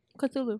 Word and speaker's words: Cthulhu: And then Cthulhu busts through the fucking window and takes him out. Cthulhu, tentacle monster. – Okay. But leaves the glasses. – Mm-hmm. I thought it was Chuchulu. – Cthulhu. – Cthulhu.